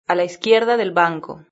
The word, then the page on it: a la izquierda del banco: European Spanish